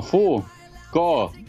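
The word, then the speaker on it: Foucault: No, no, este, este, es el chino Fu. Ko. Estás leyendo mucho fu, eh, sí, pronuncialo bien. Hay, un pequeño espacio entre medio. Es Fu Ko. – Con H al final.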